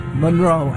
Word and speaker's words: Munro: Monroe. M-U-N-R-O. – M-U-N-R-O, but no yes.